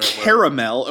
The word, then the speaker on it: Carmel: I believe it's pronounced Carmel. – Oh, see, I thought this was Carmel, like by Big Sur. – Caramel, Ohio.